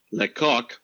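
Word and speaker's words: Lecocq: So the scientist, and this is really his name, Thomas, this is French, Lecoq. Lecoq. L-E-C-O-C-K. I even looked up YouTube how to pronounce it. It's right here. – Lecoq.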